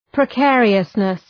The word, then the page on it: precariousness: prı’keərıəsnıs